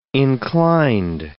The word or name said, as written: inclined